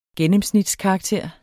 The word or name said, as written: gennemsnitskarakter